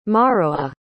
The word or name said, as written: morowa